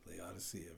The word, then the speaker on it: Laodicea: Did I pronounce that last one right? – You did. Laodicea. Very good.